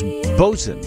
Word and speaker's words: boatswain: BOATSWAIN. It looks like boatswain is bosun.